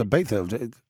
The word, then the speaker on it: Ibiza: It's Ibiza.